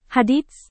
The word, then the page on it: hadits: Indonesian